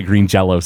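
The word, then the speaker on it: Green Jellÿ: It's a Y with little umlauts over it, and they pronounce it Green Jello still.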